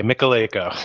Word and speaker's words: Michalejko: Silent J, Michalejko.